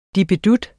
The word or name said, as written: DIPPEDUTTEN